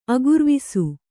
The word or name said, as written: ಅಗುರ್ವಿಸು